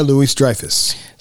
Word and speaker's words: Louis-Dreyfus: Julia Louis-Dreyfus.